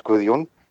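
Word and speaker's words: Guðjón: Okay, my name is Gudjon. Of course, all Americans get my name wrong, but yeah, that's okay. Gudjon is okay.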